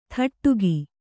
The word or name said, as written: ಥಟ್ಟುಗಿ